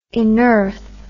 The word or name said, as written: inearth